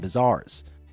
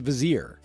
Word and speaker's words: vizier: vizars. Vizars? Vizars? How do you say that word? Wow, that's really embarrassing. It's a vizier.